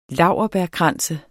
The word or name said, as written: laurbærkrans